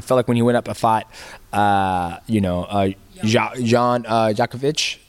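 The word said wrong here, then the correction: Błachowicz: did I say it right? – Blahovich.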